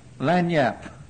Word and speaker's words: lagniappe: You tell me how you pronounce that. Lagniappe? What? Lagniappe.